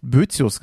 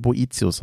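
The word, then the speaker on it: Boethius: Ich habe sehr oft Bötzius gesagt. Und auch da ist es wohl so, dass das richtig ausgesprochen Boetius heißt.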